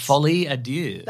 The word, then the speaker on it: Folie à deux: Folie à deux.